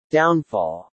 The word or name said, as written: downfall